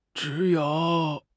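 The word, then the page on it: zhi3 you3: tone 3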